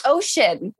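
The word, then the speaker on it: Ossian: And I was like, whoa, that's really cool because my middle name's Ocean.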